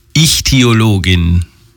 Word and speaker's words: Ichthyologin: Ja, und was soll ich sonst noch sagen, außer es folgt heute ein Alltagswissen von unserer hauseigenen Ichthyologin, von unserer Fischkundlerin Hanna R.